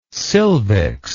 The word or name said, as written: silvics